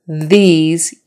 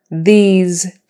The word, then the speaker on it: these: This has an is sound, short I and S, and these has a long E and Z sound.